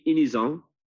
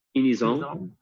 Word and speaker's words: Inizan: In French, it's Inizan. Inizan.